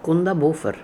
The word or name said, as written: Kundabuffer